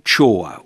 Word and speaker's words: Choir: In the middle of Mongolia, at a town called Chor, which is spelt C-H-O-I-R. – Yeah, I was hoping you'd say that, because I was going to go for choir.